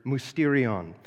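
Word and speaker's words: mysterion (μυστήριον): In Greek, I believe we would pronounce the word mysterion.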